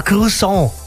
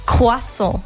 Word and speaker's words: Croissant: Also Mario, da muss ich dich sofort korrigieren. Ich habe nämlich Französisch gelernt, ja? 2 Jahre lang in der Schule. Und man sagt es eigentlich Croissant. Also das wäre die schöne, korrekte Aussprache.